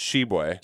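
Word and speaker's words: Tshiebwe: I have an Oscar Sheboy update, and the update is that's how they have hit the pronunciation guide in UK athletics. It's Sheboy.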